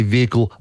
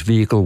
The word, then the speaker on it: vehicle: used to say vehicle. – Yep. – Like very prominently pronounce the H. – Glancing down, I have open in front of me the Oxford English Dictionary's entire database, which is some millions of words. And I just quickly dial down to their pronunciation guide. Their preferred pronunciation is vehicle without the H. – Yep. – They say with the H as an alternative, but they list them in that order.